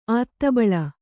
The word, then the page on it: ಆತ್ತಬಳ: āttabaḷa